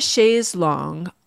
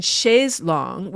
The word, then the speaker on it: chaise longue: on the chaise longue, all day long, on the chaise longue. So you've got long in chaise longue, which is spelled L-O-N-G-U-E, rhyming with all day long, L-O-N-G.